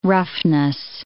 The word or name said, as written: roughness